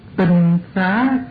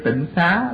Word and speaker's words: tinh xá: Chữ tinh mà sao cứ đọc là tịnh ha. Chữ này chữ tinh chứ đâu phải là chữ tịnh. Bởi vậy nên mình bây giờ kêu tịnh xá là một cái kêu sai.